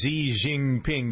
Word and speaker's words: Xi Jinping: Number 19 is Xi Jinping. Do I pronounce? I never pronounced that right. But no, that was right. That was perfect.